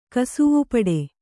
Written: ಕಸುವುಪಡೆ

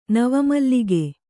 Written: ನವಮಲ್ಲಿಕೆ

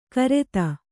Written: ಘೃತ

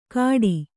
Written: ಕಾಡಿ

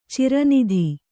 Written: ಚಿರನಿಧಿ

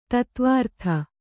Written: ಧಾತ್ವರ್ಥ

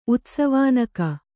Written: ಉತ್ಸವಾನಕ

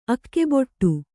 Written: ಅಗೆಬಿಡು

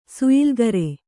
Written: ಸುಯಿಲ್ಗರೆ

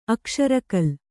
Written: ಅಕ್ಷರಕಲ್

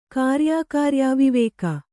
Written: ಕಾರ್ಯಾಕಾರ್ಯಾವಿವೇಕ

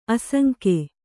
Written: ಆಸಂಗ

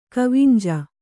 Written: ಕಾವುಂಜಿ

